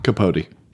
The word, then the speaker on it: Capote: Capote.